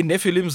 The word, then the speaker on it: Nephilim: Nee, Nephilim so geschrieben äh, und so ausgesprochen gibt es in der Bibel.